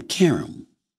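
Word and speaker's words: Qerim: Let me pronounce her name. Karam. Because she always gets on me on First Take when I don't pronounce her name right.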